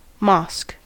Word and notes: mosque: American English

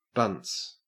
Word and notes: bunts: Australian English

